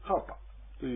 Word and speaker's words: karpa: このカラパというやつもどういう風な、文字になるかというと、ローマ字で書きますと、 K-A-R-P-A ですね。 R の発音ですね。 K-A-R-R-P-A。カラパ。ですから、カーラーパーじゃなくて、カーパーというふうに言うと、これは発音できます。